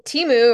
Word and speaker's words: Temu: Somebody sent me a link to I don't know how to say it, T-E-M-U, Temu.